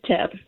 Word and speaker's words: Thip: It's probably Tip, but I was saying Thip because it's spelled T-H-I-P. – How do you say your name? – It is Tip. The Tip. H is silent.